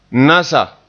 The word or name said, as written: nasa